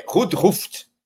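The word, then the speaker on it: Roodhooft: Então, os irmãos que são donos aí da equipe, Randhuft, né, que é a pronúncia dos caras aí,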